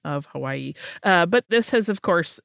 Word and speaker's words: Hawaii: You do know that in the Hawaiian language, the V sound is the correct way to say the W in the word Hawaii, right?